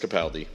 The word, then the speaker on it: Capaldi: It is pronounced Capaldi, by the way, because I asked him when we did the event last week in New York. We screened the show for the Doctor Who World Tour, and it was fantastic.